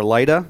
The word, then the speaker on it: Lydda: And then verse 32 picks up and says, As Peter traveled about the country, he went to visit the saints in, in Lydda or Lydda, depending on how you want to pronounce that.